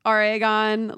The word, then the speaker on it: Oregon: Oregon. Like oregano.